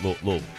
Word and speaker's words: Louisville: You know, people say Louisville, which is not how the locals pronounce it. It's Louisville. – That is correct. – Louisville. You got to say it like, again, just put a bag of marbles in your mouth.